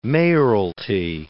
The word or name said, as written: mayoralty